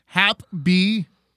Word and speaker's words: HapBee: It's this Indiegogo I saw the other day called Hap B, pronounced happy, but it's spelled H-A-P-B-E-E.